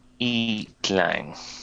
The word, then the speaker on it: Itlang: The I is a little bit like the I in machine. – Itlang. Itlang. Okay, I'm probably E-tlang. Got the emphasis on the A in terms of the syllable.